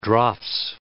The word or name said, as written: draughts